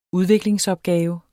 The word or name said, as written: udviklingsopgave